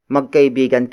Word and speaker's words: magkaibigan: For example, magkaibigan kita. Magkaibigan kita.